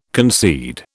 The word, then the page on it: concede: British English